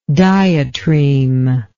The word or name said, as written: diatreme